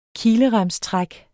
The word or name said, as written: kileremstræk